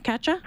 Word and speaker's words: Katja: Did I say your name right first off, Katja? – Perfect.